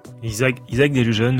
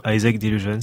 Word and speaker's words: Isaac Delusion: Depuis le début, je dis Isaac Deligen, mais si ça se trouve, Isaac Delusion ou Isaac Delusion, ça dépend. – Et vous dites comment? – On dit les deux.